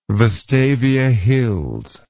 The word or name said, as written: vestavia hills